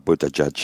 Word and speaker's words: Buttigieg: Ja, ik heb gezien dat hij zelfs t-shirts verkoopt met de fonetische eh, uitspraak voor zijn naam. Boot Edge Edge is dat dan. Ja, voilà, Buttigieg.